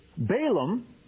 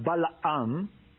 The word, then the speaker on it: Balaam: Well, Balaam, as we say in English, so in Hebrew it's pronounced Balaam, was a prophet from Pethor, which was a city on the Euphrates River.